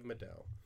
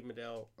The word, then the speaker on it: Medel: Medell. Last name is spelled M-E-D-E-L.